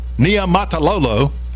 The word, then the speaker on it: Niumatalolo: I need to pull this up, because the longtime head coach of Navy, Ken Niumatalolo, I said it, y'all. I said his name right. That's not an easy name to say.